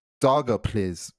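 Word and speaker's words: Daugavpils: And the name of this city, the pronunciation of this city, according to the like Wikipedia pronunciation thing, is Dogopils.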